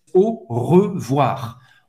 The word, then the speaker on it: au revoir: O I a gente já viu, então tem o som do WA, O, WA. Au revoir. Você vai pronunciar o R no final.